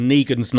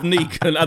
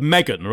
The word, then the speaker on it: Negan: And I do know how to pronounce his name, but I got it wrong. Sorry. I think I was thinking of Megan rather than...